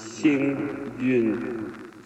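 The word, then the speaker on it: Xing Yun (幸运): But yeah, it's It's with the tone coming down, so I think it's Xing Yun.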